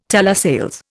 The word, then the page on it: telesales: Indian